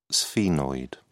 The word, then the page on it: sphenoid: British English